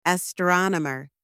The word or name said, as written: astronomer